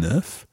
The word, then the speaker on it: œuf: So if you are looking for an egg, it's an œuf, and you pronounce the F at the end.